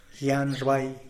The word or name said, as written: gianrbai